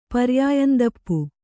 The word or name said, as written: ಪರ್ಯಾಯಂದಪ್ಪು